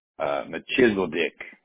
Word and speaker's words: Melchizedek: Pronounced actually better. It's more like Machizeldick, actually. Machizeldick, Melchizedek, but you want to get real precise.